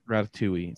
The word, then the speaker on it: Ratatouille: Ratatouille. It's no, you don't pronounce the L.